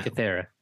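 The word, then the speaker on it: Antikythera: Once again, finding the, I forgot how to say it, Dark Amidi style. – Entikythera. – The Entikythera.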